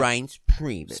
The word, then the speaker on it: Reince Priebus: Reince Priebus. – Spell it. Reince? – R-E-I-N-C-E.